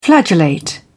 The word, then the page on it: flagellate: British English